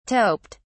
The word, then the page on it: toped: British English